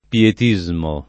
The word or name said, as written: pietismo